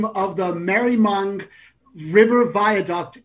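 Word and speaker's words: Maribyrnong: It's Maribymoung. So the B is silent, so it's Merrimung.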